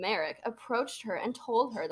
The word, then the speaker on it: Marek: M A R E K. – Ooh, is it spelled with an I? It's like Derek, but with an M.